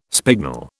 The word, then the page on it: spignel: British English